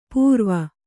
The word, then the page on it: ಪೂರ್ವ: pūrva; Kannada